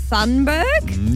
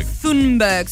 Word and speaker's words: Thunberg: Now, I do struggle to pronounce her surname, so bear with me. – Okay. – Greta Thunberg? – You're close. Thunberg.